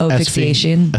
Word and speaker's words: asphyxiation: And looks like at the bar, they say that he engaged in erotic how'd you say that word, PX? – Where? – Erotic asphyxiation. – Asphyxiation. – Yeah, asphyxiation. – Asphyxiation. – Just spell it A-F-I-X.